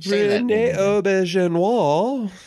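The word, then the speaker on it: René Auberjonois: Rene Auberjonois.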